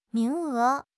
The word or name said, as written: 名额